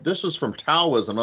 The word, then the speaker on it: Taoism: further divert this into Taoist chi theory, which, by the way, personal gripe, I keep hearing people say Taoist. Please stop. It's Taoism. It's how you say it correctly.